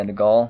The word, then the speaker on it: de Gaulle: Or Charles de Gaulle. – Yeah, de Gaulle.